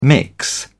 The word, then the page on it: mix: British English